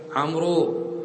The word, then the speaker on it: عمرو: هذه واو فقط حق داود الله يرضى عليه داود عطاهية ما تلفظ هذه فقط ليميز بها عمرو عن عمر.